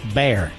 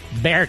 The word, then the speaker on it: Bexar: Apparently in Texas, B-E-X-A-R is pronounced Bear. Bear County. B-E-X-A-R is pronounced Bear County.